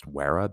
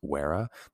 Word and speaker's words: vera: If I pronounce that correctly, it's spelled A-L-L-O-E-H, meaning bitter or shining. And vera in Latin, that would be pronounced vera because V's are pronounced as W's, vera.